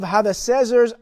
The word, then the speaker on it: Hadadezer: "And they came to Elam, and Sobak, the commander of Hadadezer's army." Did I say that correctly? Hadadezer. Yeah, Hadadezer's Yeah, I was very close.